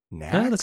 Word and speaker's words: NACS: Does anybody pronounce it that way? Is that a thing? – NACS? That's how he pronounces it.